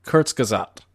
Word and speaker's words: Kurzgesagt: Kurzgesagt. – I actually, you know what? I love this YouTube channel. I believe you pronounced it perfectly or at least ridiculously close.